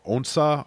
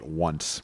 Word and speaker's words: Onsa: It is called Onsa, although everybody's going to call it Once, because that's how it's spelled, much like China Poblano is referred to frequently as China Poblano, because we're in America, damn it.